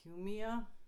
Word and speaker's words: Cumia: It was the Anthony Cumia book. – What? – Do you know Anthony Cumia? Speaking of Italians, do you know Anthony Cumia? – Cumia? – C-U-M-I-A, Cumia.